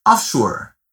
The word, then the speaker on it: offshore: A pronúncia dela é como em inglês, offshore.